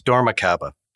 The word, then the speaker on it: Dormakaba: but could you give me the correct pronunciation of the company? – Absolutely, Alan. It's Dormakaba.